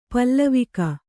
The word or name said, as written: ಪಾಲ್ವಕ್ಕಿ